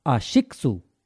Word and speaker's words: ASICS: And this week, from a Japanese friend at work, I learned that we've all been saying it wrong. – Okay. – It's not ASICS. It's, are you ready for this? – Go on. – ASICSU. – With no, there's no U in the end.